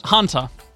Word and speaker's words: Hanta: Hinata? Hunter. H-A-N-T-A. Hunter.